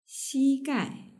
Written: xigai

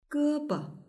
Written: gebo

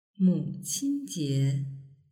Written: muqinjie

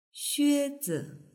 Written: xuezi

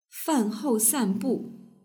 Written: fanhousanbu

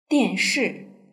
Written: dianshi

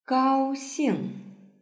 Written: gaoxing

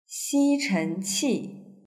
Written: xichenqi

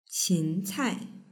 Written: qincai

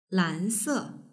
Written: lanse

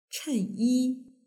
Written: chenyi

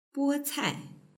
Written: bocai

